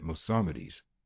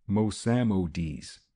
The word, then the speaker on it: Mossamedes: "That fellow was expecting trouble, and was entitled to, if it is true that they tortured him at Mossamedes." "Mossamedes," Manfred corrected the mispronunciation.